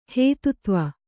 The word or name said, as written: ಹೇತುತ್ವ